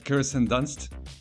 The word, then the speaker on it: Kirsten Dunst: Como en el set de Jumanji, leí que él le enseñó en el set, en lo que grababan Jumanji, a Kristen Dunst. Corrígeme por favor con la pronunciación. – a Kirsten Dunst.